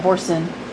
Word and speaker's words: Boursin: And it was a how do you say it, Boursin? – Boursin.